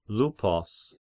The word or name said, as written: Luppus